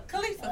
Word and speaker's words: Khalifa: Khalifa.